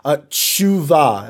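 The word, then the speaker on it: Tshuva (תשובה): Tshuva. It almost sounds like someone sneezing. In English, you'd spell that T-S-H-U-V-A-H.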